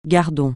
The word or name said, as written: gardon